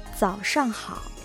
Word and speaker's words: 早上好: 早上好、早上好。 – これは早い遅いの早いという字、早朝の早という字に上、そして好きと書いて早上好と発音しますね。